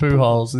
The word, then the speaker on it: Pujols: You better believe Albert Pujols is getting seriously. Is that how it's pronounced? – Yes.